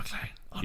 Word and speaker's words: McClane: John McClane. – He doesn't have his gun because he's on a plane. – It rhymes with plane.